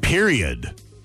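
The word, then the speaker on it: periodt: Period. Except it ends with a T, P-E-R-I-O-D-T, but it says in parentheses, what? The T is silent.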